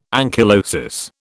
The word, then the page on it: ankylosis: British English